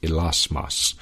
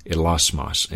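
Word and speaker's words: elasmos (ἱλασμός): Okay, in the Greek, this word propitiation in the Greek is pronounced elasmos, elasmos.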